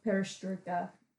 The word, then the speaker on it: Perestroika: Going back to the time of Perestroika, did I say that right? – Yes.